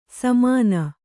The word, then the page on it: ಸಮಾನ: Kannada